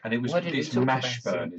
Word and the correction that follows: Washburn: is it? M A Washburn? No, Washburn. – Oh, Washburn. Washburn. Oh, spelt with a W, though.